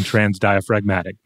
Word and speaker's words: transdiaphragmatic: I, Webster says use the hard G on transdiaphragmatic.